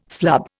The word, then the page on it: flub: Indian